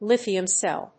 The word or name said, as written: lithium cell